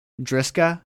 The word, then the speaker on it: Dijkstra: How do you say this? D-I-J-K-S-T-R-A. Driska? – I think so. – Yeah, Driska. That's it.